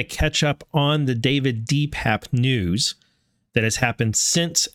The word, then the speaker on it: DePape: What I want to do is I want to go over the David DePape, which actually, because he was in court today, we learned he likes his name to be pronounced David DePape. So DePape is how he said it, DePape.